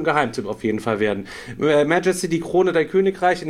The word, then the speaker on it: Majesty: ganz andere Betonung. Ja, Majesty heißt das Ding.